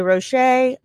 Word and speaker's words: Roche: We've got Sebastian Roche, or I'm going to say Roche, R-O-C-H-E, with the little accent on the E, a Scottish-French actor.